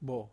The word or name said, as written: bo